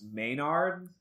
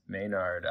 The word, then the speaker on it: Maynard: The shop owner, I believe is pronounced Maynard. – Maynard.